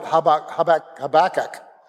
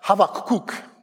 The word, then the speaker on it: Habakkuk: And many of you pronounce the prophet Habakkuk, except it's not pronounced like that. It's pronounced Habakkuk.